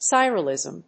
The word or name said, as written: sirolimus